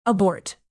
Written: abort

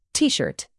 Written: tshirt

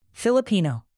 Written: filipino

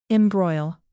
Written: embroil

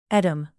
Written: edam

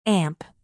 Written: amp

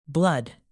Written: blood

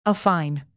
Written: affine

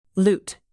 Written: loot

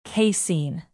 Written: casein

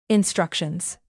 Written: instructions

